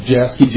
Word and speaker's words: Jaschke: Last name is spelled Jasky, J-A-S-C-H-K-E.